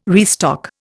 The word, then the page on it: restock: Indian